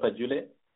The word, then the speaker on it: Pajule: Now, like in my situation, I was a pastor in that parish called Pajule. Your pronunciation was really close. The parish is called Pajule.